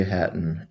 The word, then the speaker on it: Hatton: Hatton, H-A-T-T-O-N, because everyone thinks I spell it with a P.